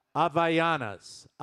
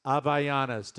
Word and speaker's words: Havaianas: They are spelled H-A-V-A-I. A-N-A-S. – Havayanas. – And it is pronounced Avayanas. Avayanas.